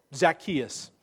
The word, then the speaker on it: Zacchaeus: One, he gives his name, Zacchaeus. That's a Greek pronunciation of a Hebrew name, Zacchai.